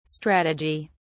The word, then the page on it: strategi: British English